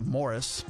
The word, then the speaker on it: Maurice: It's spelled M-A-U-R-I-C-E, which in the United States we usually call Maurice, but I think the Brits would like to call it Morris.